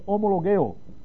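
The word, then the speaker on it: homo logeo (ὁμολογέω): Y la palabra es homologeo, así se escribe en el griego, homologeo. Así se escribe y se pronuncia así, homo logeo, que quiere decir citar un caso en la corte del cielo.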